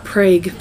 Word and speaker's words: Prague: I kept looking at Prague, and I'm like, I know it's not Prague, but for some reason I want to say it, because it's spelled like plague, but it's Prague.